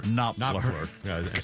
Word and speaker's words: Knopfler: The inspiration for the Sultans of Swing came from Mark Knopfler, or Knopfler? – Knopfler. – Silent K.